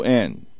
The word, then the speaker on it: Colquhoun: The individual that we're taking a look at, and I am terrible at pronouncing names, John Colquhoun. It's Colquhoun.